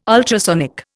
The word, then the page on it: ultrasonic: Indian